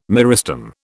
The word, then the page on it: meristem: British English